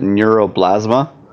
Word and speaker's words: neuroblastoma: Is it neuroblastoma? I'm guessing that's how it's pronounced. – Neuroblastoma. – Neuroblastoma, thank you.